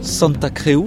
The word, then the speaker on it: Santa Creu: Alors Pierre Ducrozet, on est à présent à la bibliothèque de Catalogne, dans l'ancien hôpital de Santa Creu, c'est comme ça qu'on prononce.